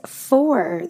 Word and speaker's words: for: Word number 13 is for, the preposition for, and it is pronounced exactly like the word or the number four, okay?